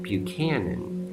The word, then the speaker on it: Buchanan: But the CH in Buchanan is not your typical CH, at least phonetically, because a CH makes a sound like cheese. But his name is pronounced Buchanan, more like cannon, and that's a C sound.